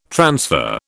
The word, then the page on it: transfer: British English